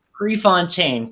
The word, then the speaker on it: Prefontaine: Am I pronouncing that correctly, Nick? – Prefontaine, close, very close.